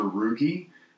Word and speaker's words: Ruge: Or you can find me on Facebook, and Facebook is just my name, Roger Ruge. Ruge is spelled R-U-G-E.